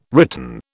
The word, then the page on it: written: British English